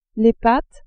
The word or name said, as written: pattes